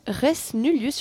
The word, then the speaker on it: Res Nullius: Ensuite, projection de Res Nullius, je ne sais pas si je le dis bien. – Oui, tu le dis bien, c'est du latin.